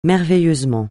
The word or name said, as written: merveilleusement